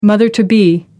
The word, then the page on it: mother to be: American English